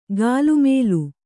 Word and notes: ಗಾಲುಮೇಲು: gālu mēlu

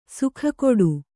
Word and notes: ಸುಕ್ಕುಗಟ್ಟು: sukkugaṭṭu

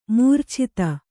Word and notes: ಮಾರೀಚತೆ: kannada

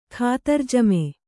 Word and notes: ಖಾತಿರಜಮೆ: Kannada

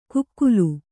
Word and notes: ಕುಕ್ಕುಲು: kukkulu

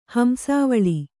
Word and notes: ಹಂಸಾವಳಿ: hamsāvaḷi

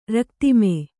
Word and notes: ರಕ್ತಿಮೆ: Kannada

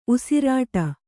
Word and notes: ಉಸಿರಿಡು: usiriḍu

